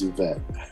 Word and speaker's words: Yvette: My youngest sister's first name is Yvette. – Okay. Really? Okay. – It is. Yeah. – Pronounce it correctly. – I know some people don't. They see the Y.